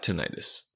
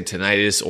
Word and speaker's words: tinnitus: And question number two, why do I have tinnitus? You may hear me say tinnitus or tinnitus in this video. Both pronunciations are acceptable. Healthcare professionals like myself typically say tinnitus.